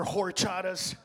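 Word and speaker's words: horchata: And I wanted to get mad, but I'm like, fuck, in Spanish, the H is silent. That's right, all you people that order horchatas. The H is silent, pendejo.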